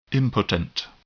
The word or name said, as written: impotent